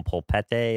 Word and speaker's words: polpette: I'm sold on every one, like the spaghetti con polpette. Am I saying it right? – Yeah, that one's great.